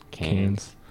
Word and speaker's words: Cairns: Cairns. – Cairns.